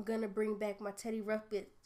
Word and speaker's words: Ruxpin: It's Teddy Ruxpin.